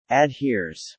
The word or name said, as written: adheres